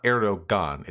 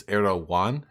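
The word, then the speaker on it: Erdoğan: So it's so to clarify, it's not Erdogan, it's Erdogan. Have I been fucking that up? – You pronounce G's like Y's, if I recall correctly.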